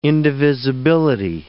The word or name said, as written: indivisibility